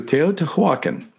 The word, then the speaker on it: Teotihuacan: Teotihuacan. That one I can do. Teotihuacan.